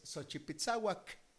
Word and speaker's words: Xochipitzahuac: También se escribe como Xochipitzahua o Xochipitzahuatl, pero la pronunciación más aceptable es Xochipitzahuac, que es uno de los cantos y danzas rituales más antiguos y sagrados de México, originario de la lengua náhuatl.